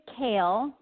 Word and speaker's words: CAAEL: I see it spelled C-A-A-E-L. How do we pronounce that? – Correct. So we pronounce it kale, like the vegetable that kale.